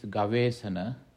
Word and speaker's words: gavesana: The first word is asana, A with the diacritic mark hyphen on top, so that's asana, pronounced longer, asana, E-S-A-N-A, long A, asana. Second word is gavesana. That is G-A-V-E, long E, S-A-N-A, Gavesana. And Gavesana, given the etymology, it is two words, Gava, G-A-V-A, plus E-S-A-N-A, Asana, Gavesana.